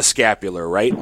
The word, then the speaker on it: scapular: My question, or if you could explain a little bit about the scapula, what you wear around your neck. is that, did I pronounce it right? – Yep, yes. Scapular. Yep.